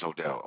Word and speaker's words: Odell: My name is Odell, O-D-E-L-L. – Odell? – Yes. – How do you spell that? – O-D-E-L-L.